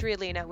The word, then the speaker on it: Rialina: Well, my name's Rialina, which is spelled R-I-A-L-I-N-A.